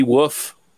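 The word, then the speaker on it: Woof: I believe that's how you pronounce it. It's W-O-O-F, Emily Wolf, as Shannon.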